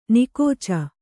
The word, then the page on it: ನಿಕೋಚ: Kannada